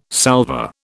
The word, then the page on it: salver: British English